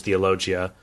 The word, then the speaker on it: Theologia: So at the beginning of the Summa Theologia, Thomas, and remember the Summa Theologia, I always say Theologica, but the correct spelling and pronunciation is Theologia, that it's a guide for beginners in theology.